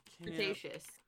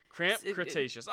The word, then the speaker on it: Cretaceous: Is it the crustacean period? What is the Cretaceous? Cretaceous. – Spell it phonetically for me. Cretaceous. Okay, I said it right. – Yeah. – Not a crustacean. Cretaceous.